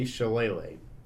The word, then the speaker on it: Shillelagh: But it's apparently Shillelagh.